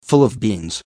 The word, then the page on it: full of beans: American English